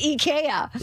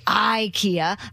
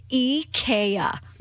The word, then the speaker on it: IKEA: Apparently it is IKEA. – Really? What? – Yes. And it's like all over Twitter now, everyone's like, don't say IKEA. That's incorrect pronunciation. It's IKEA.